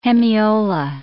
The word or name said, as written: hemiola